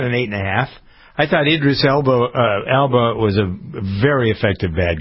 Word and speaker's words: Elba: It's A-L-B-A. – Idris Elba. Yeah, Elba.